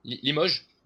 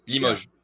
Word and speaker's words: Limoges: And so I brought up Limoges. Is that how you pronounce that? – Limoges. You speak about his team, right? – Yeah. – Limoges.